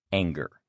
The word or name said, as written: anger